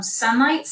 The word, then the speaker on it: Sonlight: Sunlight Science, that's a Christian version, and it's spelled S-O-N-L-I-G-H-T.